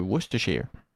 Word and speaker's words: Worcestershire: On dit Worcestershire en passant, hein. C'est pas Worcestershire, c'est Worcestershire. – Ouais, alors moi je m'aventurerais pas à la prononcer, tu vois, par exemple. – Non, je l'ai vérifié, c'est Worcestershire qu'il faut dire.